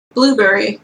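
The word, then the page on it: blueberry: Canadian English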